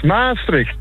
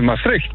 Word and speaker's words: Maastricht: Jij stuurde een appje dat ik Maastricht verkeerd uitspreek. – Ja, klopt, ja. – Wat zeg ik verkeerd? – Het is een klemtoon altijd op Maas. Maastricht. – Ja, Maastricht. – Nee, Maastricht.